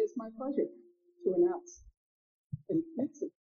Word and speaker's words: Knudsen: Our speaker tonight is Knudsen. Knudsen, the K is pronounced, the D is not.